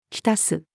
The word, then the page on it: 浸す: female